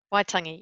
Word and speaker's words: Waitangi: The signing of the Treaty of Waitangi, is that how it's pronounced? – Waitangi, yes.